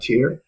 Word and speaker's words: Tyr: Yeah, uh, Tyr T Y R tier tier stray.